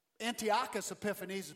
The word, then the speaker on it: Antiochus Epiphanes: Antiochus Epiphanes, the Antiochus Epiphanes is correctly pronounced, from the north came down and stripped them.